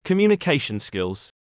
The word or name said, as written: communication skills